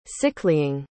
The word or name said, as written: sicklying